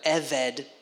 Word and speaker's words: Obed: The Hebrew word Obed, or Eved, is really kind of how it's pronounced. Eved, you might see it in other names and characterizations of people in the Old Testament if you read the Old Testament carefully.